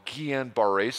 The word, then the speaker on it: Guillain-Barré: 14 years ago, my dad got a bout of what was called Guillain-Barré syndrome.